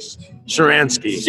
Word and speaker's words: Shcharansky: Shcharansky.